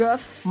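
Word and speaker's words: Gough: Celestial Toy Maker é aí interpretado pelo Michael Goff. Não sei como pronuncia direito. – Guff.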